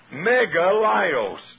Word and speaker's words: Megalios: Megalios, M-E-G-A-L-I-O-S. Let me spell it again. Megalios, M-E-G-A-L-I-O-S.